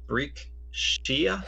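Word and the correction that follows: Brek Shea: No, Breck Shea.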